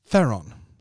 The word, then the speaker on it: Theron: Theron, ska man säga. Theron. Ja, jag kom upp i min feed, eh, Charlize förklarade hur man faktiskt uttalar hennes namn. Det är inte Theron, utan det är Theron.